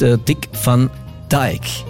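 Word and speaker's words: Dick Van Dyke: Einige der ältesten noch lebenden Musiklegenden präsentiert er uns in dieser Woche, und auf Platz Nummer 4 seiner letzten ist Dick van Dijk. Den spricht man so aus, tatsächlich.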